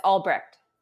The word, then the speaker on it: Albrecht: It's Albrecht, so it's German. You can spit at the end if you want.